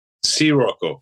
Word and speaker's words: Scirocco: One of the songs you sent us, I'm going to ask you to help me pronounce it. Is it Scirocco? – Scirocco.